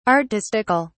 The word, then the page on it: artistical: British English